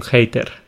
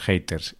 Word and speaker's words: hater: Mm, a ver, aquí nosotros la pronunciamos más o menos mm, de una forma parecida a la pronunciación original, pero cuando tenemos una H, nosotros en español solemos hacer un sonido muy J, hater. Para nosotros es como una J.